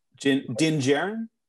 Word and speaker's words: Din Djarin: And just finding out the sorry, the Mandalorian Dijon. How do you pronounce that name? Pronounce it like Jin. – Jin, yeah. – Din Djarin.